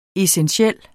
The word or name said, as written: essentiel